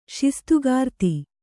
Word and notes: ಶಿಸ್ತುಗಾರ್ತಿ: kannada

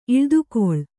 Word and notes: ಈಳ್ದುಕೊಳ್: kannada

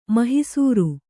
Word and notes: ಮಹಿಸೂರು: mahisūru

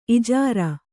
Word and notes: ಇಜಾರೆ: ijāre

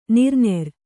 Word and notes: ನೆರೆನೀರು: nere nīru

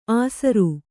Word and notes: ಆಸರು: Kannada

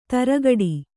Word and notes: ತರಗಡಿ: targaḍi

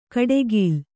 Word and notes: ಕಡೆಗೀಲ್: kaḍegīl

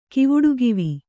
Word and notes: ಕಿವುಡುಗಿವಿ: kivuḍugivi

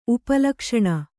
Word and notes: ಉಪಲಕ್ಷಣ: upa lakṣaṇa

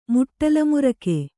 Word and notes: ಮುಟ್ಟಲುಮುರಿಕೆ: muṭṭalu murike

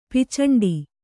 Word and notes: ಪಿಚಂಡಿ: picaṇḍi

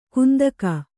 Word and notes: ಕಂತೆಗ: Kannada

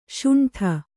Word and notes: ಶುಂಠಿ: śuṇṭhi

